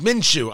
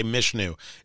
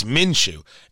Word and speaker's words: Minshew: My thanks to JMV 93.5, uh, The Fan, the voice of sports in Indiana, and it's Minshew. I sometimes confuse it and say Mishnu. It's Minshew is how you pronounce uh, Gardner Minshew's name. It's Minshew.